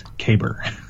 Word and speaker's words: Kaber: The definitive pronunciation of Kaber is Caber. K A Y B E R.